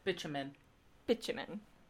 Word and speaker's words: bitumen: Bitumen. – So like bitch. – Yeah. – Bitumen. – Okay. Bitumen. I never would have pronounced it like that.